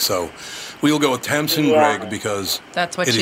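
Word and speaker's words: Greig: It's Tamsin Grieg, to rhyme with Greg.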